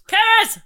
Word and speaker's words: Cerys: Keris! – It's Welsh, lol, because it's spelled C-E-R-Y-S, but he's written, pronounced K-E-R-I-S.